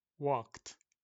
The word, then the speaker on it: walked: Cuando les agregamos ed al final quedan trabajé, worked, caminé, walked, walk, walked, work, worked. Debes de notar que no se dice walk o work sino que suena una D seca, como una T, walked, para que sea pasado. Walked, worked.